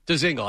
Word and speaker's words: Dzingel: Is it Dzingel or Dzingel? Dzingel. I had it right.